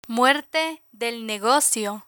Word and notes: muerte del negocio: Spanish

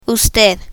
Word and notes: usted: Spanish

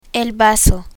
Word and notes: bazo: Spanish

